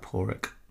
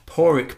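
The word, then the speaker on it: Pádraig: You pronounce that Porik. – Thank you. – Yeah. – Porik Belton. And I do apologise to Porik. – Sorry. Like Porik Harrington.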